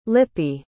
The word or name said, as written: lippi